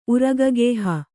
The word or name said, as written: ಉರಗಗೇಹ